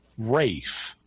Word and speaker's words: Ralph: I'm not sure how he pronounced his first name either, because the name Ralph, R-A-L-P-H, I know this one. – Is sometimes pronounced Rafe. – Yeah. – That's like the L drops out.